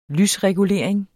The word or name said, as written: lysregulering